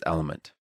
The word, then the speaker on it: LMNT: That's, let me try to spell it out, D-R-I-N-K-L-M-N-T dot com slash Lex spelled LMNT, but pronounced element.